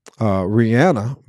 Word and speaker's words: Rihanna: And yes, I'm saying Rihanna, because she said in an interview that people call her Rihanna, but her name is Rihanna.